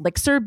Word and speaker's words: Lixr: So this brand is called Lixer Beauty. It's spelled L-I-X-R.